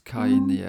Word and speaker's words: Kanye: Och hon ska ju skilja sig nu från sin eh, Keanu West, eller vad, hur han nu uttalas, Kanye, mm.